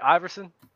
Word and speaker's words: Iverson: Iverson? – Yeah, go ahead.